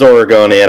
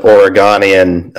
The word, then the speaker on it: Oregonian: It is Oregonian. I've had people call it Oregonian in recent weeks, and that was less than ideal because, you know, it's not Oregon, it's Oregon.